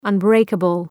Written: unbreakable

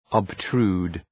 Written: obtrude

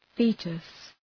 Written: foetus

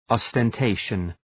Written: ostentation

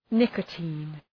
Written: nicotine

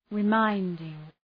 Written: reminding

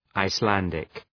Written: Icelandic

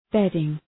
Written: bedding

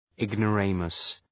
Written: ignoramus

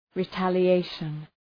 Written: retaliation

